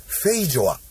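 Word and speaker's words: feijoa: 本田さんにも試してもらいたかったこのフルーツは、日本語発音でフェイジョアと言います。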